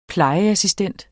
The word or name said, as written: plejeassistent